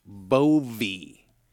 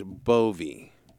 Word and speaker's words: Bowe: But she spells her name B O W E. But it's pronounced B Bovey. B O W, and it's pronounced Bovey.